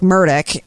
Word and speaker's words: Murdaugh: I've been watching the Murtalk, It's Alex Murdaugh, but it's pronounced Alec Murdock, and that's because there's a lot of southernness going on in this trial because it's in South Carolina.